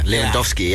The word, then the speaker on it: Lewandowski: In Poland, the W's are pronounced as V's." Now, that answers someone who asked me why I couldn't call him Robert Lewandowski. It's because it's actually a V rather than a W.